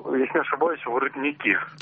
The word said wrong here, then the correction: воротники: Я вам прощу ударение, потому что в том-то весь... Я прощу вам ударение. Алло? – Да. – Все правильно, только они воротники.